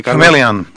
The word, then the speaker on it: Chameleon: Chameleon, o Chameleon, o como se pronuncia. – Chameleon.